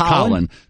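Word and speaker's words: Khalen: I think Colin Saunders, it's K-H-A-L-E-N pronounced Colin Saunders, defensive tackle, Western Illinois.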